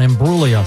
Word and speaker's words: Imbruglia: Yeah, sometimes tough to pronounce, but the G is silent on Imbruglia.